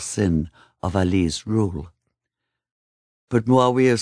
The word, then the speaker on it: Muawiyah: The slinky sinuousness of the 4 drawn-out syllables of the name, Muawiyah, seems almost tailor-made for the sheer curses that would be heaped on it in centuries to come.